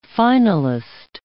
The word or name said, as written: finalist